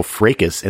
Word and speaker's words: fracas: You know what I found out today is that the word fracas is pronounced by some people fracas, and that both versions are correct.